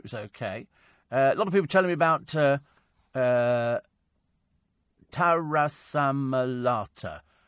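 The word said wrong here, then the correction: taramasalata: It's Taram Asalata.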